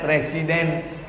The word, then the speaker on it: Residence: Tulisannya Residence, cara bacanya Residence. Inilah bahasa yang tak sesuai antara tulisan dengan bacaan.